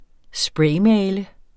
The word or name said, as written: spraymalet